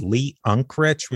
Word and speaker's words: Unkrich: It's the director was Lee Unkrich, which is interesting because Unkrich.